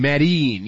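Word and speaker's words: Medellín: That's actually how you say it. I know in Entourage they say Medellin, but it's actually Medellin. They say Medellin. – Medellin. Yes, it's Medellin is how it's actually pronounced.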